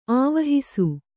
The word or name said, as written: ಆವಹಿಸು